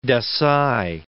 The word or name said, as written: Desai